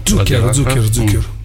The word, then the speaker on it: Zucchero: Zucchero.